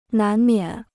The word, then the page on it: 难免: nán miǎn